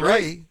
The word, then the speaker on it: Rea: No, but remember my last name is spelled R-E-A, Dan Ray, and that's the first letters of the word reason.